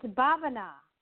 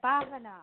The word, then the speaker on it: Bavana: My name is Bhavana. – I'm sorry? Could you say that again? – It's Bhavana. Bhavana. – Bhavana. Yes, could you spell that for me? – B-A-V-A-N-A.